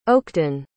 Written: Oakden

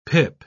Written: pip

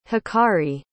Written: hikari

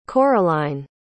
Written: coraline

